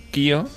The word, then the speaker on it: Qihoo: Es Kio 360. Q I H O O 360.